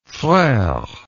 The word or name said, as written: frere